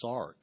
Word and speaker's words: sarx: Now, the Greek word for flesh, if you spell it in English, it's sarx, S-A-R-X. It even sounds bad.